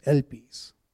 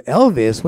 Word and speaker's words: elpis: E-L-P-I-S, elpis. Somebody, I was preaching one time, and he goes, Elvis? What do you mean Elvis? I said, no, it's not Elvis. It's elpis. Because that's how you pronounce the word, elpis.